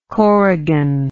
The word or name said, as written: corrigan